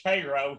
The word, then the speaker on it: Cairo: yes, actually, we pronounce it Cairo here, but uh, yeah, that's... you know, it's crazy. These different pronunciations tend to Very state to state.